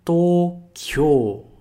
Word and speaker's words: Tokyo: Mas em japonês esse kyo é uma sílaba só. Tokyo, ok? E não esquece de prolongar esse to.